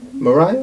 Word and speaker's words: Moriah: And go to the region of Moriah. Did I say that right? – Moriah.